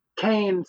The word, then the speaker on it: Qayin: Cain, spelled with a Q, so Q-A-Y-I-N, the Liber Falsifer series.